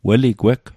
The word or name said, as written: weligweg